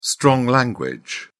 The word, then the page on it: strong language: British English